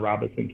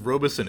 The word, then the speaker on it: Robeson: I've always heard it pronounced Robeson. It's cool to actually hear how it's supposed to be pronounced. – Yeah, there's no robe in Robeson County.